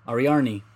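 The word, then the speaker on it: Ariarne: Australia's I cannot pronounce her name captures gold in Ariarni.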